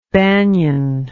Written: banian

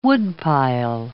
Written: woodpile

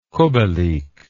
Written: kubelik

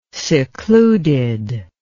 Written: secluded

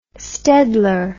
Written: staedtler

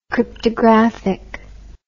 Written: cryptographic

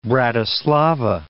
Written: Bratislava

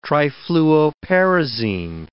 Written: trifluoperazine

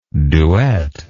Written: duettist

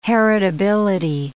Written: heritability

